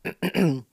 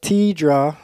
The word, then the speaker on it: Teedra: All right, so Tiadra, Mo- uh, Tiadra, Teedra, I guess is how you pronounce the name,